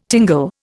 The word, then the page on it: tingle: Indian